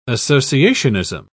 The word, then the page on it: associationism: British English